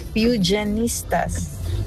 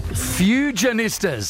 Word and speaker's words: Fujianistas: It's pronounced as Fujianistas. – Fujianistas. So I was almost there.